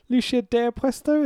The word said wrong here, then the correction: Lucha de Apuestas: Lucha de Apuestas? – Oh, that's why.